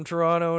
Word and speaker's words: Toronto: It's Toronto. You don't pronounce that second T. What the hell's the matter with you? it's only people who are from Toronto know that second T is silent.